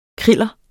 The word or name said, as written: kriller